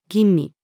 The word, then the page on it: 吟味: female